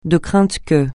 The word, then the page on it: de crainte que: francais